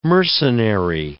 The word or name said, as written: mercenary